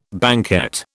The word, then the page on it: banquette: British English